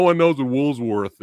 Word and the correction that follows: Woolworth: is. – Woolworth. Yeah. – Because I was saying it wrong.